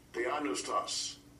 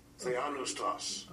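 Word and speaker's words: Theopneustos: Here's Strong's G2315. Theonoustos. Theonoustos. – So you can even get the proper pronunciation.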